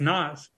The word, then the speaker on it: Nas: Is it pronounced Nas or Nas? – Is it Pam or Pam? – I mean, It's Nas. – Yeah, Nas is like the Fast and the Furious.